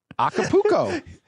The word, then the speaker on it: Acapulco: Yeah, I'm glad you said it correctly. Acapulco.